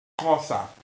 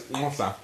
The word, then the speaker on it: Xhosa: That's how you spell it. It is pronounced Hosa. – I know about Hosa. Yeah. I don't know how to spell it, though. Yeah. – You have to click it, so it's Hosa.